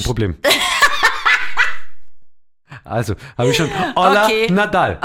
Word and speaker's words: hola: Olla, ja. Das Haar spricht man nicht. Viele sagen Holla.